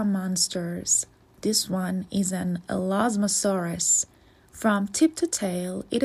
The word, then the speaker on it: Elasmosaurus: Elasmosaurus. Say Elasmosaurus.